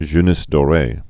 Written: jeunesse doree

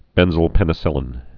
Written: benzylpenicillin

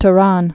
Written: Tourane

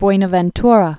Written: Buenaventura

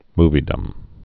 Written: moviedom

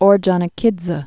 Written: Ordzhonikidze

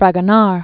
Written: Fragonard, Jean Honore

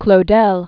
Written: Claudel

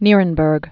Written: Nirenberg

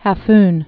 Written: Cape Hafun

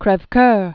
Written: Michel Guillaume Jean de Crevecoeur

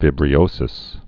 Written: vibriosis

